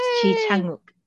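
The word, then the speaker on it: Ji Chang-wook: So again, Ji Chang Uk. – Ji Chang Uk. Is that okay? Ji Chang Uk. – Very good. Ji Chang Uk. Yay!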